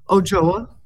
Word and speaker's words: Ochoa: Did I say your name right? Andreas Ochoa? – Ochoa. – Ochoa. – There you go. – Okay. – The last part is hard.